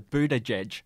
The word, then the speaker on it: Buttigieg: Just on Pete there, fun fact, he's been handing out little badges at his campaign rallies that have a how to pronounce his surname with the words boot, edge, edge. So Buttigieg.